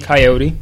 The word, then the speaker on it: Kaiyote: Not pronounced, I mean, it's not spelt like Coyote. I believe... It's spelled K-A-I-Y-O-T-E. Coyote? – That still seems like Coyote. Yeah, I can see that being Coyote.